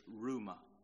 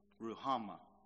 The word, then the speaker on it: Ruhama: Hosea said, say to your brethren, Ami, or some people pronounce that Ami, and to your sisters, Ruma. Ruhama.